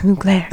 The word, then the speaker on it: Mugler: When she's like, take me out, this Mugler. And I was like, yeah, that's how you say it.